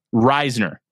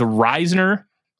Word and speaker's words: Risner: Now, it is pronounced Risner. – Not Risner. – Risner. They confirmed it. It is Risner. – Okay. – Those at the press conference did confirm that that was the pronunciation.